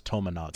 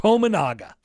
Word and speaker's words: Tominaga: Wouldn't you just say Keisei Tominaga? Maybe you would say Keisei. – Yeah, there is no reason in Year of Our Lord 2024 to mispronounce Tominaga. – Tominaga looks exactly how it looks.